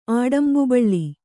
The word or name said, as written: ಆಡಂಬುಬಳ್ಳಿ